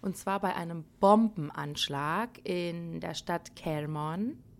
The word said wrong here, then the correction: Kerman: Habe ich es richtig ausgesprochen? Kerman, oder? Ja.